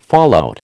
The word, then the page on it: fallout: American English